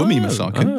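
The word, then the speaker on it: Wunmi Mosaku: Wumi, actually, it's Wumi Masaka.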